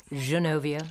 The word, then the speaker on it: Genovia: Remember, we used to think it was Genovia, and we finally decided we would be given the French pronunciation. – Yes. – Genovia.